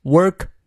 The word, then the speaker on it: work: is a chance chance 元音念到位啊，如果是英式发音就是 chance chance to work hard at work worth doing，好几个 w o r，那么它的元音呢是发长 a a work work，嘴型扁平一些，不要念成 walk，嘴型太圆了，变成了走路那个单词哈，work hard at work worth doing worth。